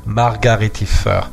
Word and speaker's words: Margaritifer: friends of mine said, oh, it's a margarita, like the cocktail. I'm like, no, it's margaritifer. But, well, fuck it. I mean, people will pronounce it their way.